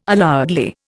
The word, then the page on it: allowedly: Indian